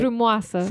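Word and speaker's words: frumoasă: Yeah, our teacher would always say, I'm teaching you Limba Romana Frumosa. Did I say that right? – Frumoasa. – Frumoasa. It's been a long time.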